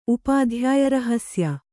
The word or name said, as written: ಉಪಾಧ್ಯಾಯರಹಸ್ಯ